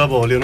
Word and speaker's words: Avolio: È stato perfetto, Avoglio, non lo azzecca mai nessuno, di solito. – Okay. – Lo sbagliano sempre.